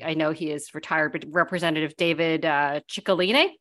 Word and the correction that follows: Cicilline: am I pronouncing that right? Cicilline.